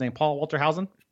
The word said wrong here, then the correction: Hauser: Paul Walter Hauser, yes, from Richard Jewell, which I saw this past weekend.